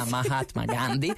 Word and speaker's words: Mahatma Gandhi: Mahatma Gandhi 7, l'ho detto bene, via Mahatma Gandhi,